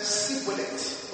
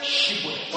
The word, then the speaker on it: Shibboleth: If he says no, then they said unto him, say now, Shibboleth. – But he said, Sibboleth. – Sibboleth. – For he could not frame to pronounce it right.